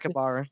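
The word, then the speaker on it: Sakakibara: Zoffer, apparently, back in the day, paid the ex-Pride CEO, Nobuyuki Sakibara. Is that how you pronounce it? – Sakibara, yeah.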